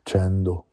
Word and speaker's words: Sandeau: Sandeau, si scrive così, ma si pronuncia ovviamente Sandeau.